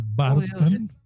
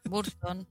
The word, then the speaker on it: Burton: Así como Tim Burton, en lugar de Barton. Burton.